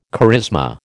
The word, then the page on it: charisma: American English